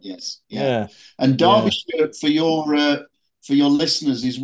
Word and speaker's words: Derbyshire: Now you live in, and I'm gonna say it wrong, Derbyshire. – You did say it wrong. That's right. It's Derbyshire.